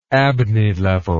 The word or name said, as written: abney level